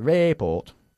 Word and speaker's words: report: Okay, according to the report, not the report. Okay? According to the report. – According to the report. – No, no. Estás en el report. – Report. – Report. Enfatizando la segunda sílaba ahí, ¿vale? – According to the report.